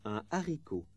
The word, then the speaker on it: haricot: Most words starting with an H have this liaison, but there are some which don't. Un haricot. Listen and repeat.